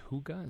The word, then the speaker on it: Hygge: it's called, pronounced Hygge, is that right? – Very good. – But it's spelled completely differently. You would not recognize, H-Y-G-G-E.